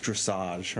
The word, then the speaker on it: dressage: There was a horsewoman who like pulled out of the, it's called, it's pronounced dressage, right?